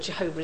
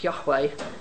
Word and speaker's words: Yahweh: Actually, of course, we now know that Jehovah is not the correct pronunciation of God's name. It should be Yahweh Jireh. They don't have Jeh in Hebrew, actually. I'm not Jenny, I'm Yeni. they don't have a Jeh sound.